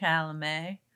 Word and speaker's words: Chalamet: Chalamet? – Yes.